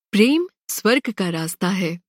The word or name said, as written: स्वर्ग